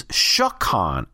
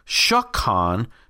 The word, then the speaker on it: shakan: And the verb to dwell is shakan, S-H-A-K-A-N. Right? Can you hear the similarity even? Mishkan, tabernacle, shakan, to dwell.